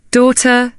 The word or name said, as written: daughter